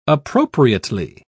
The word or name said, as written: appropriately